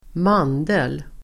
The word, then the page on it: mandel: [²m'an:del]